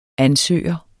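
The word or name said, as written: ansøger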